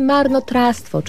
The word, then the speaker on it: marnotrawstwo: Marnotrawstwo piszemy z dwiema literkami W. Marnotrawstwo. Ale mówimy marnotrawstwo, czyli w wymowie pomijamy pierwsze W, ale nie możemy go pominąć, pisząc ten wyraz.